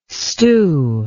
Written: Stu

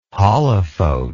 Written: holophote

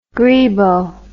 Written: grebo

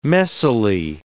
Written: messily

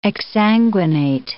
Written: exsanguinate

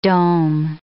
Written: dome

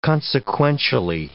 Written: consequentially

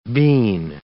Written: bean

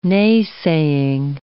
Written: nay-saying

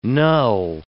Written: null

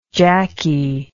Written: jackie

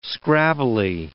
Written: scrabbly

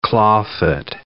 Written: claw-foot

